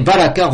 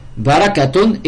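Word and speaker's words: Baraka (بركة): Là, par exemple, on peut appliquer, donc vous voyez, baraka, revenons à baraka. Barakatun et le ta marbuta, ça se prononce ha. Baraka. Quand on s'arrête dessus. Baraka. Quand on fait la liaison, barakatun. Baraka.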